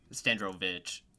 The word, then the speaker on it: Strandrovich: How do you say your last name? Can we just do this now? – Yeah, sure. It's Strandrovich. – Strandrovich. Well, why is there a W? – It's Polish, and you still said it wrong, but that's okay.